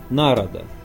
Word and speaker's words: Народа: Тут же на приполярном Урале была гора Народа, ударение на первый слог, высшая точка всего каменного пояса. В нормальной речи звучало как Народа.